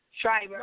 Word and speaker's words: Shirer: I quoted I quoted him in in my book, and I also quoted um, Priscilla Shriver. Shirer. uh-huh. yeah. Oh, that's how you say it.